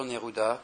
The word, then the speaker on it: Neruda: Alors, ce n'est pas un livre, c'est une interview qui a été donnée il y a une quinzaine d'années par Dr Neruda, comme Pablo Neruda, comme ça se prononce, mais c'est un ancien membre des services secrets qui a déserté, en fait, qui était au courant de beaucoup de choses.